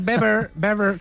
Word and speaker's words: Weber: Está Manfred Weber, que se dice sí, Weber, sí. No se dice Weber, ¿vale? Sí, sí. Se dice Manfred Weber.